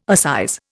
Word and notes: assize: Indian

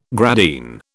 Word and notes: gradine: British English

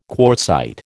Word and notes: quartzite: American English

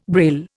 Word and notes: Braille: Indian

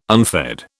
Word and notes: unfed: British English

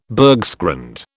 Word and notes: bergschrund: British English

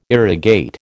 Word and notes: irrigate: American English

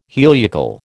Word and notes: heliacal: American English

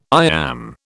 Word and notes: iamb: British English

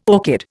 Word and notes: orchid: Indian

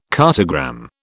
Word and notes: cartogram: British English

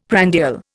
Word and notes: prandial: Indian

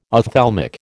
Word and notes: ophthalmic: American English

